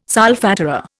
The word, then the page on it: solfatara: Indian